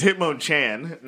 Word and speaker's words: Hitmonchan: You said it wrong. It's Hitmonchan. – Not Champ.